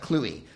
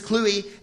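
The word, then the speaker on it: Kluwe: His name is pronounced like Cluey. Thanks for the awesome Proopcast. I look forward to hearing more, JW. Yes, it's Chris Cluey, and he does not play for the Ravens, as previously asserted on this podcast.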